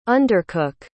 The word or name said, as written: undercook